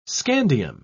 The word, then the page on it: scandium: British English